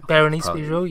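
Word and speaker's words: Bérénice Bejo: Martina Guzman and Berenice Bijou. – Berenice Bijou, yes. – Yeah, there we go.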